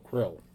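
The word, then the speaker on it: K'rrll: It's K-apostrophe-R-R-L-L. Pronounce it however you feel like it. – Kirill. – Was there a vowel in there somewhere? What was that? – No. K-apostrophe-R-R-L-L. – So Krill.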